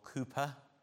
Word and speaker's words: Cowper: And then later, in the 1700s, William Cowper, or Cooper, as some actually refer to him, I think is really the more correct pronunciation.